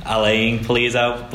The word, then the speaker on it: Alan Pulido: Yeah, Alan Pulido. I think he pronounces more Alain Pulido, but, you know, it is how it is.